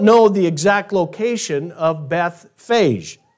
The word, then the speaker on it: Bethphage: As Jesus draws closer to Jerusalem, he comes upon 2 villages, Bethpage or Bethphage or Bethphage is how it's pronounced in the Greek, and Bethany.